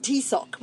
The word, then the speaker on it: Taoiseach: Now it's spelled Taoiseach. – Oh, okay. – And then, but it's actually pronounced Taoiseach, which is hilarious, like, to be sure. Tea in my sock.